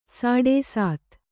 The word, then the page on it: ಸಾಡೇಸಾತಿ: sāḍē sāti